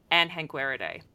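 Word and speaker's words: Anhangueridae: And then we've got our family, Anhanguiridae. That's how I pronounce it. It is based on, like, the first Anhanguera was named from Brazil. And when I went to Brazil, I'm pretty sure I pronounced that word around some colleagues and they didn't correct me.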